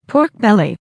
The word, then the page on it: pork belly: American English